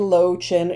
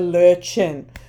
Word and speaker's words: Löwchen: So if you want to pronounce the breed correctly, it's not a low chin; it is a Löwchen.